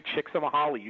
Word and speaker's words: Csikszentmihalyi: I mean, Csikszentmihalyi, you said it right, and every time I try to do it on my own, I stumble.